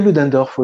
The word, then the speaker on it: Ludendorff: Est-ce la même chose que la guerre totale théorisée par Ludendorff bah, Si je prononce bien. – Oui, oui, Ludendorff, oui, oui.